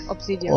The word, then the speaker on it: Obsidian: Es ist ein Rollenspiel von Obsidian, was auch aussprechen mag. – Ja, Obsidian.